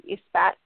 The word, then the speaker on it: Esbat: And from a very traditional witchcraft perspective, there's the Espat, the Espat, the Espat, however you want to pronounce it, E-S-B-A-T.